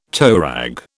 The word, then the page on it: toerag: British English